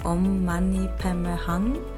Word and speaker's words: Om Mani Padme Hum: Also ich habe Om Mani Padme Hum oder richtig ausgesprochen Om Mani Padme Hum erwähnt und ein ebenso sehr, sehr kraftvolles Mantra ist für mich Om Nama Shivaya.